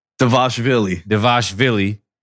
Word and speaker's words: Dvalishvili: I'm going to butcher this name. Mirab Dvalishvili. – Close. – Give it to me. – Dvalishvili.